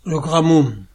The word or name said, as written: Gramont